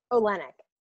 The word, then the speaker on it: Olenick: So it's Alyssa Olenek.